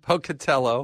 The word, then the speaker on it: Pocatello: Pocatillo. Did I say it wrong? – Pocatello.